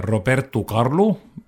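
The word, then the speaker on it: Roberto Carlos: Igatahes selline väga põnev laul minu arust ja laul on siis meie saates esimesena kõlanud Roberto Carlo, portugali keeles siis õige hääldus, ja Erasmo Carlo, ühis pala, mis on siis väga kuulsaks saanud maailmas.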